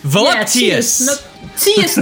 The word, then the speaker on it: voluptuous: Voluptuous. Teus, not churus.